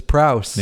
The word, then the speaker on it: Prowse: David Prowse. – You were right. Oh, nailed it. You were right. It's Prowse. Nailed it. David Prowse.